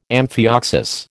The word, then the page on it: amphioxus: American English